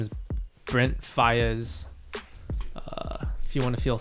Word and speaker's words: Faiyaz: Brent Faiyaz. – Faiyaz. – Faiyaz. – Yeah, it's spelled in a pretty weird way. – F-I-Y-A-Z. – Close. Brent F-A-I-Y-A-Z.